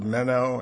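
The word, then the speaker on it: meno (μένω): Abiding is the word meno, M-E-N-O, long O at the end.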